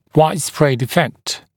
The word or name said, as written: widespread effect